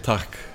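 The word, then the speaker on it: takk: Tak.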